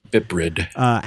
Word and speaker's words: BPRD: I think that's pronounced Biprid.